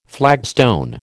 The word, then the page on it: flagstone: American English